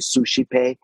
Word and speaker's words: Suscipe: He would have used the Spanish pronunciation, and so you can use different ones, but he would have probably, for those who know the Spanish, it would be Suscipe, but it's just...